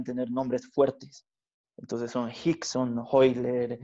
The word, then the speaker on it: Rickson: Eh, eh, por cierto, todos sus nombres son con R, como Rickson, pero pero en portugués eh, lo pronuncian como J, Hickson, mm.